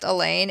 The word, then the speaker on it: Alain: Sometimes pronounced Elaine, A-L-A-I-N. – Yeah, my brother-in-law really specific. Alain. Yeah.